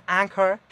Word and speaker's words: Anchor: Y bueno, Anchor, que se pronuncia Anchor.